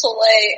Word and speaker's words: Soleil: Where I had, like, a substitute teacher, and, like, they pronounced my name wrong, and everyone in the class, like, laughed and everything. I'd be like, no, like, it's Soleil, and then they'd pronounce it wrong again.